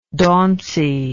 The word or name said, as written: dauncy